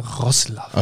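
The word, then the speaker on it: Wrocław: okay, also nächstes Spiel haben wir die Panthers aus, äh, äh kannst, kannst du es richtig für mich aussprechen? Breslau oder Ros Roslav. – Alles, Ros Roslav.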